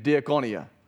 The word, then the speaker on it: diakonia: It's pronounced like this, diakoneo and diakonia.